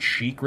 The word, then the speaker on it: chic: And I'm like, Chic. It's pronounced Chic. Why are you guys calling him Chick? – Just throw that Chick.